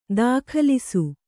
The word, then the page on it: ದಾಖಲಿಸು: dākhalisu